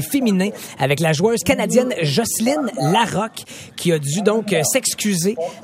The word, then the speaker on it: Jocelyne Larocque: Jocelyne Larocque, on doit prononcer Jocelyne Larocque. C'est comme ça qu'on prononce son nom véritablement ici.